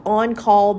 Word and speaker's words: en caul: And I said at one point, I remember sitting on the birthing ball and I said, I think on-call births are so cool. And before I go any further, let me explain what an on-call birth is. On-call is a French word. It's spelled E-N C-A-U-L, on call.